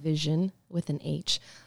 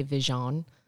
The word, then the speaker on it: Vishion: Firstly, thank you for pronouncing it right. Mm-hmm. Vision with an H. Um, so, it happens a lot more than you would think. For some reason, white guys love to say Vision.